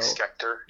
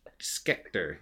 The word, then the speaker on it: Schechter: Spell it. – Just Harold S-C-H-E-C-H-T-E-R. – Like Schecter. – Schecter. Yeah, there you go.